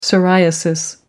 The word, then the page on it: psoriasis: American English